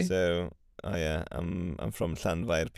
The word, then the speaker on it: Llanfair: So we've got llan. – Llan. – Yes. Fair. – Fair. – So that's llan fair.